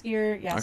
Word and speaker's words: Jaskier: it's spelled Jaskier. – Okay. – So it's Yesdear.